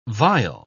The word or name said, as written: vile